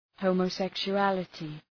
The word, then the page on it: homosexuality: ,həʋmə,sekʃʋ’ælətı